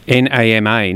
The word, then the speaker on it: nama: You'll see the NAMA. Nama, is that how you say it? – Nama, yeah. So nama, if we take it classically from Japanese, means raw. So raw, and so nama sake is unpasteurized sake.